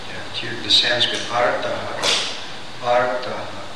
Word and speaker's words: artha: And then the seeker of wealth, artha, that's with a short A, the TH, artha, arti. Artha, arti, the seeker of wealth. Artha, and artha, artha, and Arta and Artha Arti. Get that aspirate in there.